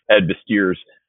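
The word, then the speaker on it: Ed Viesturs: I often use the mountaineering example of Ed Visser's is how you pronounce it.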